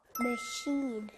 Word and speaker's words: machine: Machine. – Yes, machine. 여기 슛 소리가 나요.